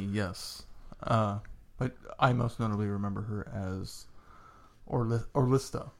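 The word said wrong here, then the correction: Ollistra: How do you say it? – Alistra. – Yeah. Cardinal Alistra from the War Doctor box sets.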